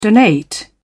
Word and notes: donate: British English

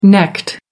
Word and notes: necked: American English

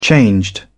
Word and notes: changed: British English

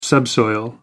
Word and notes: subsoil: American English

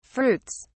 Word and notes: fruits: British English

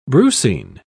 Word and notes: brucine: British English

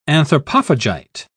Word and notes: anthropophagite: British English

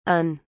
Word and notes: UN: British English

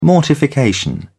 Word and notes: mortification: British English

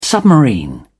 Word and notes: submarine: British English

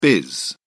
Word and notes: biz: British English

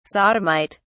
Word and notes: sodomite: British English

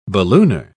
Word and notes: ballooner: British English

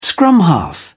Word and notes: scrum half: British English

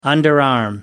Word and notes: underarm: American English

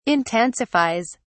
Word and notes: intensifies: British English

